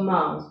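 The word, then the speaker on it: Magrs: When I read the book, in the foreword, the new foreword by Marina Warner, she writes that you and co-creator Paul, is it Margaret? – Paul Mars. – Mars. – Yeah, silent G.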